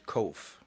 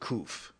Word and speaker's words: Kof: And some people pronounce it Kuf. Depends, you know, what, you know, like you say tomato and I say tomato. You say potato and I say potato. Some people say Kof, some people say Kuf, all right?